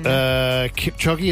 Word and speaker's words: Kipchoge: Kipchoge, is that how I say his name? – Yeah.